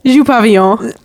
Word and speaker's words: Dupavillon: Dupavillon. – Okay. So I got to be more French in order to pronounce your name, then, I guess.